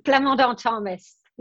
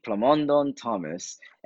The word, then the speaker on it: Plamandon-Thomas: Plamandon-Thomas. Yeah, the French-Canadian way. – I hear the French in it, yes. So Nathalie Plamandon-Thomas.